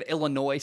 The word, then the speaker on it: Illinois: The way the state of Illinois spells things, the S is silent. I hate that so much. Anyway, why include the S, I guess, is my point. It's Illinois is how it's spelled, but it's Illinois is how you say it.